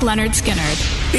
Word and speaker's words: Lynyrd Skynyrd: Lynyrd Skynyrd, pronounced Lynyrd Skynyrd.